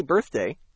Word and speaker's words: birthday: When I say birthday, do I need to blow air at the end of the first syllable? – The answer is yes.